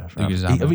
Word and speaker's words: Leguizamo: I always go Leguizamo, but he doesn't. I'm sure you know Sid is his most famous one, and he fucking nails that. – Leguizamo, I think, is how you pronounce it.